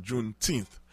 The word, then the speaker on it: Juneteenth: prononcer le comme ça Juneteenth